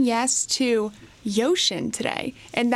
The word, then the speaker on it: Uxin: It is spelled UXIN, so the pronunciation there might be a little different than people were expecting, but it's the largest used car dealer in China.